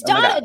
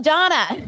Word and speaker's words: Dana: Donna is spelled D-A-N-A, but it's pronounced Donna to confuse people like Zachary Reality.